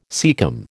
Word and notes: caecum: American English

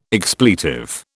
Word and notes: expletive: British English

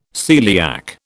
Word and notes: celiac: British English

